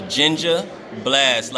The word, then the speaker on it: Ginja Blast: It's Ginja Blast, like Ninja Blast, but ginger and ninja and blast, because that's exactly what it does to your system.